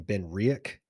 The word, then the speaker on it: BenRiach: I've adopted saying BenRiach, like R E I E K, I guess, BenRiach, because that's how they say it over there.